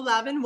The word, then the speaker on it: Lavin: It's Lavin, not Lavin.